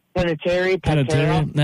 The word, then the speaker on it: Panettiere: How do you say her last name? Hayden Panettiere? Panettiere? – Panettiere.